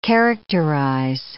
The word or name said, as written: characterize